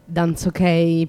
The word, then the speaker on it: Dansokey: Danzok, per chi non mastica, anch'io non mastico, che tra l'altro non è neanche inglese, perché si scrive DANSOKEY, danzok, quindi mm-hmm.